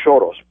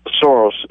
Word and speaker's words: Soros: The proper pronunciation of his name, actually, because he's Hungarian, unfortunately, is Soros, because S in Hungarian is pronounced Sh. So it's really Soros, but it's pronounced Soros in English.